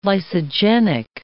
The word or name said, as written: lysogenic